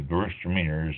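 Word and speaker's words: Gewürztraminer: G-E-W-U-R-Z-T-R-A-M-I-N-E-R, pronounced Gewurztraminer. The W is sort of a V sound.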